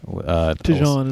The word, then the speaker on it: Tijaon: And Tijon, I don't know how to say his name, T-I-J-A-O-N. – Tijon is what I said.